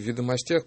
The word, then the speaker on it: Ведомостях: Меня тут упрекают, почему я все время говорю в «Ведомостях», потому что так правильно, потому что так меня научил Марина Королева. – Ударение на последнее слово.